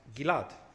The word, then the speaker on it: Gilad: For example, as I said, Gilad becomes Gilad. Gilad is pronounced Gilad. Different pronunciation.